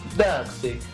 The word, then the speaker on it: Darkseid: Darkseid. No es francés. – Tampoco italiano. Sí.